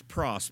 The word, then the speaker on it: pros: It's a little word you pronounce pros, P-R-O-S, a prefix.